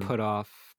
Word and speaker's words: Puthoff: And then as advisors, you have people like Dr. Hal Puthoff. – Mm. – I think I'm pronouncing that right. – Yeah, Puthoff. Basically, it says it all. Put off.